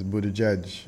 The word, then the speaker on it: Buttigieg: Peter, the pronunciation is Buttigieg.